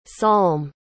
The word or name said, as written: salm